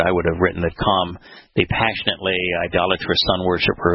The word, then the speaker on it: Cham: Ham, C-H-A-M, so it's the hard C-H-A-M, Ham, which means passionately idolatrous sun worshiper, who was the father of Canaan.